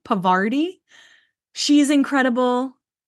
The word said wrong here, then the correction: Parvati: Is it Parvati? – It's Parvati.